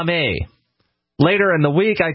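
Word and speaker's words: Dame (だめ): Dame! I yell in Japanese, which means bad. Did I pronounce that right? – Dame.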